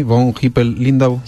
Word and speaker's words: von Hippel-Lindau: Ese nombre alemán tan complicado, ¿es así? Von Hippel-Lindau, sí, o algo así. Sí, está perfectamente pronunciado.